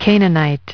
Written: Canaanite

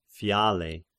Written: Phiale

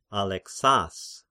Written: Alexas

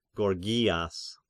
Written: Gorgias

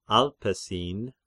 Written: Alpesin

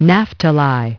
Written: Naphtali

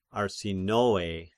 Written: Arsinoe

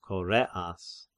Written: Koreas

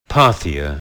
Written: Parthia

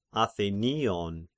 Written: Athenion

